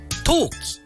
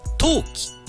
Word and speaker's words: 冬季: 冬の季節は冬季、はい、そして不要なものとして投げ捨てるのは冬季、はい、違いはございません。同じアクセントになります。